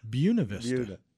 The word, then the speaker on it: Buena Vista: Do you know how they pronounce it? Buena Vista. – Buena.